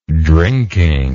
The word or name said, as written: drinking